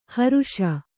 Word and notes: ಹ್ರೇಷೆ: hrēṣe

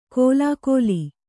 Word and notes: ಕೋಲಾಕೋಲಿ: kōlākōli

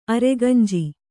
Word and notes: ಅರೆಗಂಜಿ: aregañji

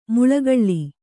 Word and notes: ಮುಳ್ಳುಗಳ್ಳಿ: muḷḷugaḷḷi

